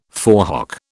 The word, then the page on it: forehock: British English